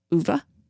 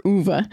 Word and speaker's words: Ove: Like you guys haven't heard it yet, but we'll talk about a man called Uwe. Apparently, that's how you say it.